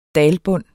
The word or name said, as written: dalbund